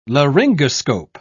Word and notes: laryngoscope: British English

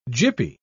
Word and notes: gippy: British English

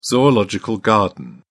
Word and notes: zoological garden: British English

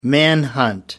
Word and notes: manhunt: American English

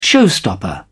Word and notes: showstopper: British English

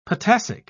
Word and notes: potassic: British English

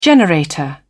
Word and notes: generator: British English

